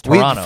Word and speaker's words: Toronto: We say, that's weird because we say Toronto, but every here everyone says Toronto. – Yeah, there's no hard T in it, right? It's Toronto. – For some reason, we just stopped pronouncing it.